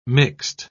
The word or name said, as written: mixed